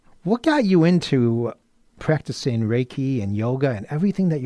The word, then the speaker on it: Reiki: It's pronounced, I said Reiki, but it's pronounced Reiki. – Reiki. – Like with a rake. – Like you're raking.